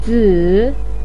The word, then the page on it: zi: 3